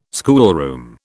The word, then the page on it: schoolroom: British English